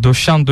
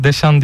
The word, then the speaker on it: Deschamps: Yani bunu Türk spikerler özellikle Fransızca'da böyle E'ler Ö okunur mantığıyla döşan döşan aynen diye vurguluyorlar. O yanlış bir telaffuz, onu bir söyleyelim. İki evet. Saint-Joseph'li olarak orada E'den sonra S'ye geldiğinde onu Deşan diye okumak gerekiyor.